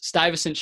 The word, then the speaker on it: Stuyvesant: Your dream scenario, some artist writes a song about, I want to make sure I'm saying this right, Stuyvesant, a different emphasis, Stuyvesant champagne.